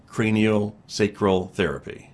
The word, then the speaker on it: craniosacral therapy: craniosacral therapy.